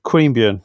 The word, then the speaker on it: Queanbeyan: Is that how you pronounce it? – Do you know what? I'm not sure what the correct pronunciation of, as a pretty bogan Aussie, I would probably say Yokine, but that's probably wrong. But you certainly got Queanbeyan correct.